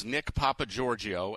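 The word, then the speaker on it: Nick Papageorgio: Nick Papa Giorgio, are you good with that pronunciation on the 8? – Well, first of all, it is Nick Papa Giorgio, and Nick Papa Giorgio was the fake ID name that Rusty Griswold used in the movie Vegas Vacation.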